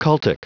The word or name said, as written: cultic